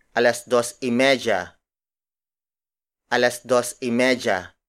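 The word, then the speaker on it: y medya: If it is half hour like 2:30, we add y media. Y media. Y media. Y media is spelled as Y space M-E-D-Y-A.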